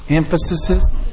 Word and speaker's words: emphases: I said that word right. Emphases. Emphases. Okay, I didn't say it right.